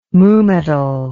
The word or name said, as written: mu-metal